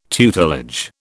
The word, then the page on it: tutelage: British English